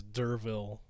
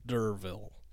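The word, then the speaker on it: D'Urville: How do you say that name? – I believe it's Durville. – Durville? – Okay. D-apostrophe U-R-V-I-L-L-E. Durville.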